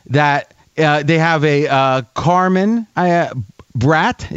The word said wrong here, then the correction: Brate: Is that how you say his name? Uh, Cameron Cameron Brate.